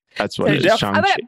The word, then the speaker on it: Shang-Chi: Shang-Chi. – Well, because it was like Sean, like he's Sean. Like Sean, your yeah. name is Shang-Chi.